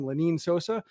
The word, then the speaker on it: Lenin Sosa: So good news is that I went there to look at Lenin Sosa, Lenin Sosa. I've been pronouncing his name wrong the entire time, Lenin Sosa.